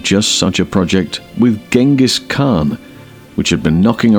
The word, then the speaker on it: Genghis: Turns out it's Genghis with a G, I know.